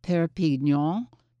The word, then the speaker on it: Perpignan: Like, you can actually go right over the border there to, I'm going to mispronounce this one, though. I think it's Perpignan. It's right over the border into France.